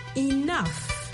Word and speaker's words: enough: Ça s'appelle E-N-O-U-G-H. Et bien sûr, ça ne s'appelle pas comment ça s'entend. Ça s'entend comme E-N-O-F. Enough. Le G-H en anglais se prononce souvent comme un F. Et le O-U, en fait, ça se prononce comme un « oh, enough, enough ».